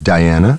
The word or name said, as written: Diana